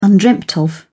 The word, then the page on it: undreamed of: British English